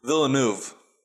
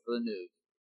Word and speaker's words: Villeneuve: How do you pronounce Villeneuve? – Villeneuve. – Villeneuve.